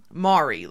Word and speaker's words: Maury: And so our county is named from Abram Maury, and some of his descendants sound pronounce their name Mari, like Maury Povich.